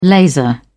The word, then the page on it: laser: British English